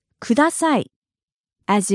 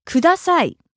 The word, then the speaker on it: kudasai: Today's phrase is kudasai.As in asking for something or someone to do something for you. In Japanese, please is kudasai. Kudasai. Broken down by syllable, it's kudasai.